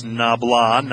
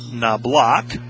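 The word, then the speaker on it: Knoblauch: Patrick Nabala back into the contest. And for those of you who heard some earlier ballgames, yes, it is Nabla, not Nablock, as I was mispronouncing early in the season.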